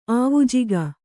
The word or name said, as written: ಆವುಜಿಗ